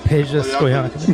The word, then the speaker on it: Peja Stojakovic: Peja Stojakovic.